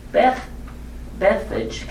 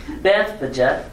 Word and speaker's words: Bethphage: Bethphage. Can y'all say, wait a minute, I didn't say that right, kids. Bethphage.